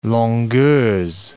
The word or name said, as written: longueurs